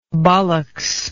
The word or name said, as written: ballocks